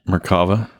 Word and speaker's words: Merkava: Merkava.